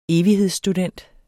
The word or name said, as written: evighedsstudent